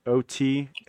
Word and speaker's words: Otaku: I'm gonna try to pronounce it, and I'm pretty sure you're gonna do it. Tokyo Otaku, Otaku. – Oh, I said it right.